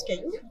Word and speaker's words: Caïu: Donc euh, Caillou qui s'écrit pas tout à fait comme un caillou, mais qui se prononce Caillou.